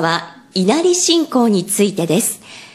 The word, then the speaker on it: 稲荷: 川尻さん、どうして狐とこの稲荷が結びついたんでしょうか。 – そうですね、まずはじめにこの稲荷、稲の荷物の荷と書いて稲荷と呼んでいますけれども、